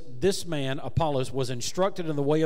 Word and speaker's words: Apollos: In Greek, that would be pronounced Apollos.